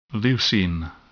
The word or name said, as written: leucine